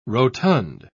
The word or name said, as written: rotund